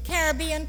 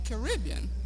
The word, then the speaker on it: Caribbean: I've heard both Caribbean and Caribbean. – Well, both are acceptable, I believe. In the New Webster's Dictionary, Caribbean is first, as the preferred pronunciation, but both are listed. Which do you use? – Well, I say Caribbean.